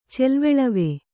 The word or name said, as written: ಚೆಲ್ವೆಳವೆ